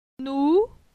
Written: Nou

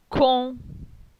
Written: Con

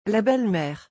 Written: La belle mere

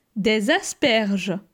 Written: Des asperges

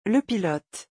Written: le pilote

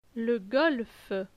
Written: Le golf

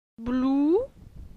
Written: Blou

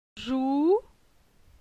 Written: Jou

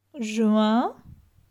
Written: Juin

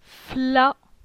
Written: Fla

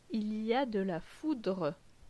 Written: Il y a de la foudre

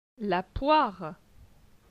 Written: La poire

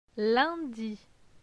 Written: Lundi

